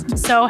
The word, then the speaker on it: Tso: So has a T, a silent T. Like General Tso's chicken.